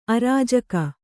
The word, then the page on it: ಆರ್ಜಿಕೆ: ārjike